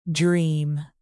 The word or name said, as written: dream